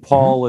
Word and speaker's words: Paul: It's German. Right. And Paul is fine, just like P-A-U-L, okay. Even though it's P-A-H-O. – Okay, cool. Very good. You've said that before to people, I assume. – P-A-U-L.